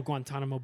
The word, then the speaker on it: Guantanamo: like the original Guantanamo Bay, which if I'm not mistaken is down there in Cuba.